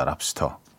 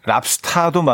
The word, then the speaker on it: 랍스터: 정답은 3번 랍스터였습니다. 랍스터. 네. 아, 뭐 우리식 발음 랍스타도 맞는 걸로 하겠습니다.